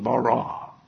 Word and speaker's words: bara: Short, two syllables, bara.